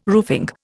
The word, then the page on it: roofing: Indian